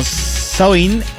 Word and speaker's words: Samhain: Les decía hace un momento que la festividad de Samhain, pronunciado aproximadamente como Samhain en las lenguas gaélicas,